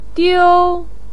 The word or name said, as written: diu1